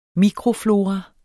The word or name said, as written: mikroflora